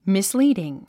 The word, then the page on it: misleading: mislíːdiŋ; ミスリーディン